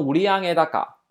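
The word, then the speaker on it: 売上高: 读成五力啊，读成五力阿盖大嘎啊，五力阿盖大嘎。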